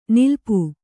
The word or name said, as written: ನಿಲಿಪು